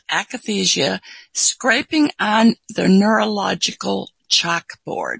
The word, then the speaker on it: akathisia: Well, I see you speak here about, I don't know how you say it, akathisia? – Yes. – Yeah, and akathisia.